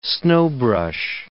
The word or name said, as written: snowbrush